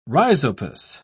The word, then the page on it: rhizopus: British English